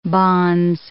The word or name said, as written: bonze